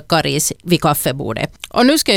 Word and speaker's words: Karis: Rekommendationen om långt A, Karis, har funnits sedan början av 1960-talet, och för det så fanns det faktiskt inte uttalsrekommendationer överhuvudtaget.